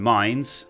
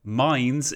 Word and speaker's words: Mainz: By the way, before we go any further, a huge thanks to my wonderful patron, David Lund, for letting me know that Mainz is not in fact Mainz. I apologize, I don't really know why I was pronouncing it as Mainz all this time. I mean, I can understand in the one sense why I would, but yes, I feel like Mainz makes a lot more sense.